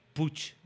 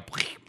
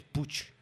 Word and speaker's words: Puig: Mira, por lo menos, lo bueno que tiene todo este follón de Cataluña es que por fin la gente de España ha aprendido a pronunciar Puch. En vez de... porque la gente siempre decía Puig. – Claro, ahora ya saben que es Puch.